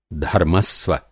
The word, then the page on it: धर्मस्व: Hindi